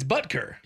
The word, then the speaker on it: Butker: Is it Butker, right? – I don't know how you pronounce it. I think it's Butker. – It's Butker.